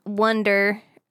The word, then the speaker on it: wonder: I do have a question that this has like sparked in me, because we're talking about the magic and the wonder. I'm flipping on my words today, but the wander, how do you say it? – Wonder. Wander is like when you're lost.